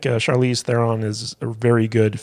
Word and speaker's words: Charlize: Well, that's our review of The Old Guard, the Charlize Theron movie.